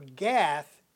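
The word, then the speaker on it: Gat (גת): The Hebrew word for the word valley is G-A-I, or gay, G-A-Y, if we want to spell it with our I and Y sounds. The Hebrew word for Gath is Gat, G-A-T.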